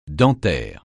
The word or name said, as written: dentaire